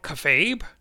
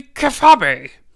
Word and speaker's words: kayfabe: See, a lot of people will think it's called kayfabe or kayfabe. It's actually kayfabe. You've been mispronouncing it all these years, and I think it's warranted that you correct yourself.